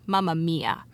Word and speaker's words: Mamma Mia: Mamma Mima. – I think it's pronounced Mamma Mia.